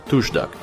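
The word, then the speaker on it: torsdag: Э, Туждак, туждак, он произносится, потому что шведы О периодически как У произносят.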